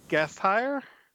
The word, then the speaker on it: Gasteyer: How do you say it? Because I butcher it every year. – I believe it's Gasteyer. – Gasteyer is fine.